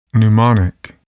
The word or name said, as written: pneumonic